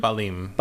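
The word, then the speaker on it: Basim: How do you pronounce his name? Balim.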